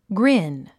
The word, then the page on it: grin: ɡrín; グリン